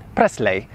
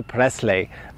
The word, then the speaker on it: Presley: I can't remember how many times I've heard in Poland, Polish people say, not Presley, but Presley, Elvis Presley. Never ever say Presley.